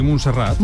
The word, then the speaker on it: Montserrat: ¿Montserrat? – Sí, Montserrat.